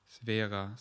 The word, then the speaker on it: Swera: S-W-E-R-A. – Svera, Svera ja.